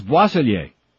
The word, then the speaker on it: Boisselier: Her name is Boisselier. I didn't butcher it. – You didn't? – No, I don't know my French very well, but this one is not really that hard, George.